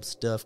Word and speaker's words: Stuhff: This happened in early 1970s when a young attorney by the name of Michael Stuff, I call him Stuff because it's spelled S T U H F F.